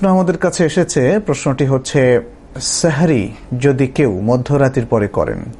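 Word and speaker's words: সাহুর (سحور): সাহরি মধ্যরাতের পরে করলে কোনো অসুবিধা নেই, তবে সাহরির ক্ষেত্রে, যেটাকে আরবিতে সাহুর বলা হয় মূলত, সাহার অথবা সাহুর, বাংলা যদি আমরা সাহরি বলি, শব্দটার আরবি উচ্চারণ হচ্ছে সাহুর, এই সাহুর যদি কেউ মধ্যরাতের পরে তিনি নেন অর্থাৎ পানাহার করেন তাহলে কোনো অসুবিধা নেই, যদি তিনি দেরি করেন সেটাই হচ্ছে সুন্নাহ।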